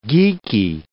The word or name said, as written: geeky